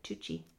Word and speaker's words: Tucci: How do you pronounce it? Stanley Tucci? Tucci? – So. That's what I said. Tucci. – Tucci. Yeah, I think you're right. Tucci.